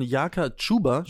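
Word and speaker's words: Jaka Čuber: Äh, Grund ist folgender, es, ist, also, es liegt an der Verpflichtung von Jaka Chuba, spricht man ihn, glaube ich, aus. – Ja, ja.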